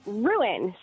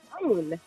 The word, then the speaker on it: ruin: Ruin. Sounds like two syllables, right? Ruin. So I say ruin. My husband says rune.